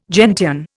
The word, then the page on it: gentian: Indian